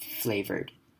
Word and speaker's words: flavored: Fruit flavored。好 ,fruit flavor 这个字是口味，那这边呢主要特别重要特别注意的是 ,or 这边要念成 r 的声音 ,flavor。好，那后面的 ed 呢我们也不会念 ed, 不会把母音 e 念出来，我们会假装 r 后面直接接 d, 所以会念 flavored。